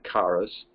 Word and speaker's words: charis: which in some Greek lexical aids is spelled K-A-R-I-S, and others it's spelled C-H-A-R-I-S, but it's the word charis.